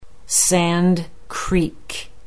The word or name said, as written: Sand Creek